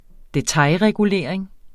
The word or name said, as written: detailregulering